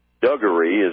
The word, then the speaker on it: Dougherty: versus Dougherty, D-O-U-G, like Doug, H-E-R-T-Y. U.S. versus Duggery is the way it's be pronounced phonetically, but it's D-O-U-G-H-E-R-T-Y.